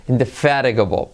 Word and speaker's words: indefatigable: Pero en este caso vamos a decirlo, fijaros cómo se dice, se escribe indefatigable. Indefatigable. Pero se dice indefatigable.